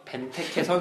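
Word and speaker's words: Benteke: 벤테테, 벤테케 선수의 조금 발음 좀 그렇죠.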